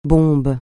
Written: bombe

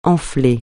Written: enfler

